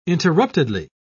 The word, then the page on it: interruptedly: British English